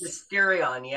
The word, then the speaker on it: Mysterion: Mysterion, yeah. – Mysterion. I left a vowel out. Sorry. – That's the American way of saying it, yeah.